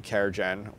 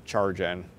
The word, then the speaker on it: CharGen: 19 UDP, CareGen, or sometimes CharGen, depending on how you pronounce it.